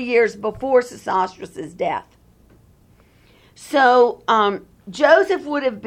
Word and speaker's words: Sesostris: During the 12th dynasty, at the beginning, there are two great pharaohs, Sesostris II and Sesostris III. S-E-S-O-S-T-R-I-S, that's the way it's pronounced.